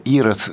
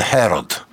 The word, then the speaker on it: Ирод: Ну, так же точно, как у нас Ирод ассоциируется с иудейскими царями, а это греческое имя, которое вообще у нас так произносится по-славянски, вообще оно скорее должно произноситься Херод.